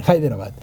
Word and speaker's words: Hyderabad: Hyderabad, sí, lo dije bien, lo dije bien, Hyderabad, de la India Británica.